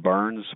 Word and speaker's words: Byrnes: So feel free to Google me, William Burns. My last name is spelt, I say Irish, B-Y-R-N-E-S.